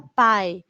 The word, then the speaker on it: pie: Lo pronunciamos como en inglés. ¿Por qué? Porque si escribimos pie y pie, si se dan cuenta es lo mismo, pero pie puede ser foot. Cuando te duele tu pie, una parte del cuerpo o un pie. Por eso no lo pronunciamos en español como pie.